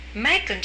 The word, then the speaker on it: Macon: It rhymes with bacon. – Macon, Georgia, wasn't it?